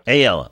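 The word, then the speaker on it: AELL: What is the proper pronunciation of the acronym? I've heard it at least two ways, depending on how folks handle the double L. – AELLA. – Okay, good. I think I was going AELLA, but I think that's because I heard a couple people say it. So we'll go AELLA.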